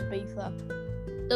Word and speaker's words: Ibiza: Nah, it's at least spell it in Spanish, it's Ibiza.